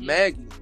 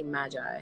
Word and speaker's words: Magi: Yeah, because I know they say Magi, because I always said Magi. – But then the show says Maggie. They associate Magi with, like, magic. – Yeah, I know. – Yeah. – I'm but not saying it's y'all not, wrong. I'm just saying it's Maggie.